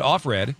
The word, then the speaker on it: Offred: They pronounce it Offred.